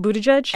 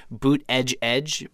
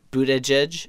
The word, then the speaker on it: Buttigieg: He has on his site Boot Edge Edge, Boot Edge Edge, I guess, Buttigieg, and then sort of Buttigieg.